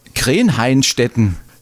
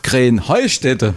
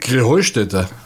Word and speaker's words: Krähenheustätte: Ja, ihr saget immer Krenheinstetten. Und dabei heißt es doch Krähenheustätte, oder wie ist es? Krähenheustätte. – Ja, genau, und das ist die Betonung auf dem Heu.